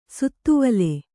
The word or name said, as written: ಶಾದ್ವಲ